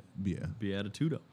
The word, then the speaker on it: Beatitudo: B-E-A-T-I-T-U-D-O. Beatitudo. – Oh, Beatitude.